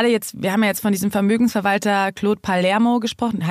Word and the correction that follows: Palmero: Heißt er Palermo? – Palmero. Äh, Palmero, aber äh, äh viele sprechen ihn. Er wurde schon auf die unglaublichsten Weisen ausgesprochen. – wir sagen jetzt mal wieder richtig, Claude Palmero.